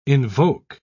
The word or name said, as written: invoke